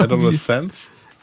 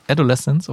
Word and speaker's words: Adolescence: Erstmal mit der Aussprache vielleicht. – Genau, Adolescence. – Adolescence, okay. – Adolescence. – So wie Evanescence, okay.